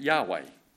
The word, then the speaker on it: Yahweh: We pronounce it Yahweh.